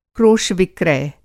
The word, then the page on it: क्रोश-विक्रय: Hindi